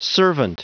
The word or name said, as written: servant